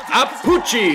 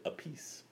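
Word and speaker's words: Appice: My brother pronounces his last name differently than I do, which is pretty weird, Apucci. – Apucci. – That doesn't look like... – I don't know how to write the phonetic alphabet. Apucci. I like that. It's probably actually... it's probably accurate. Nice Italian from Brooklyn. – Okay. – For the record, his brother Carmine pronounces it a piece.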